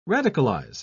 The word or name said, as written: radicalize